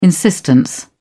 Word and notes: insistence: British English

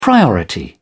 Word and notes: priority: British English

